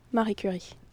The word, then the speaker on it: Marie Curie: Marie Curie. – Only a Canadian with an appropriate training in the French language could say that quite so well.